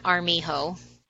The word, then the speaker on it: Armijo: and that is Jill Dawn Armijo. John is Dawn, Jill is Jill. My last name is spelled Armijo at Gmail. So Jill Dawn Armijo at Gmail.